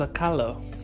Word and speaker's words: Sakala: Notice that the A's in the back of Balinese words are pronounced like E's, they are like closed A's. So it's spelled Sakala, but it's actually pronounced Sakalo.